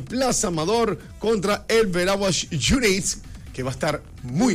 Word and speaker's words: United: pero Veraguas United se pronuncia en inglés, United, no, una palabra en español y una en inglés.